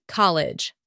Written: college

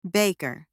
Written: baker

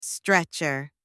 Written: stretcher

